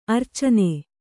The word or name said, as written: ಅರಚಣ್ಣ